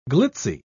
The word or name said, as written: glitzy